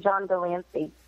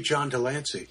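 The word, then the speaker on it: John de Lancie: It would be John DeLancey. Yes. – Good job! You're learning. – And I will. For 5 years, I called him John DeLance because I'm an idiot.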